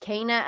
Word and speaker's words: Kena: Yeah, no, we actually, before we went on, we were talking about how it's pronounced Kana, and yeah.